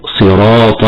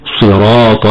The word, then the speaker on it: صراط: كلمة صراط تقرأ بتفخيم الصاد كما قلنا في قوله تعالى: اهدنا الصراط، لكن من غير ضم الشفتين في حرف الصاد، هكذا يكون النطق الصحيح: صراط الذين أنعمت عليهم.